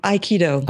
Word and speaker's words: Aikido: Can you sort of relate that to us, not only in the, and I know I said it wrong to begin with, what's the correct way to say it? Aikido? – Aikido.